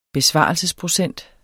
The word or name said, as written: besvarelsesprocent